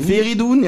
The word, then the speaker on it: Feridun: Und Freidun Zaimoglu. – Erstmal steht da Feridun. – Ja, Feridun, ja, Feridun Zaimoglu.